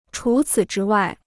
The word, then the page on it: 除此之外: chú cǐ zhī wài